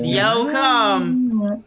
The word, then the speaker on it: Yocum: Dallas Yoakum. – Oh, wow. – And it's spelled Y-O-C-U-M. Dallas, yo, come.